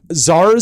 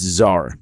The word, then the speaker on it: Czar: It's not Caesar face. It's not scissor face. – Because everybody can't pronounce that. – It. Yeah. – Czar is a Russian word. It's czar. Yeah. Get it? Czar. – The C is silent.